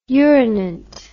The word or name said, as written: urinant